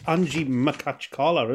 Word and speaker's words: Anzhi Makhachkala: And it's Anzhi Makhachkala, isn't it? – Right, M-A-K-H-A-C-H...